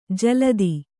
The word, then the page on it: ಜಲಧಿ: Kannada